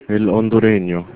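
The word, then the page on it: hondureño: Spanish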